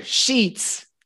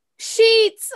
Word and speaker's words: sheets: With Spanish speakers not being able to pronounce sheets, sheep, and beach. So actually this long stressed E sound is actually a difficult one for almost all students learning.